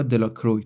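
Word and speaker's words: Delacroix: بابی و هری جونز و دیکی دلاکرووا اهالی دهکده او را دلاکروی تلفظ می کردند.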